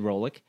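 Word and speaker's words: Roglič: And in the end, they came out smelling like roses because they isolated Rolic, that's how you pronounce his name officially.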